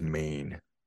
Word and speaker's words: Men: It's called Ennis Main, and Main is it's spelt Ennis Men, but the Cornish way of pronouncing MEN is Main.